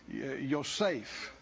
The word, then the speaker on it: Yosef: or that's how we pronounce it. It'd be Yosef, I think is how it is, maybe more correctly.